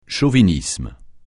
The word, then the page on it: chauvinisme: français